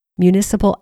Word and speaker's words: municipal: And by the way, in Spanish, it's pronounced municipal. Next, parish or parochial, which is pronounced parochial, albergues are run by the local church or diocese.